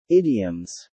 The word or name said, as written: Idioms